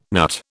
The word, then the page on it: NUT: British English